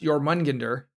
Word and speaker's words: Jormungandr: All right, and then I believe it's pronounced Jormungandr, as it's named after the Norse world serpent.